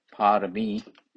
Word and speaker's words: Parami: Parami pronounced P A, which is long A, R A M I, Parami, and O Paramita, P A, A long A, R A M I T A, long A, Paramita.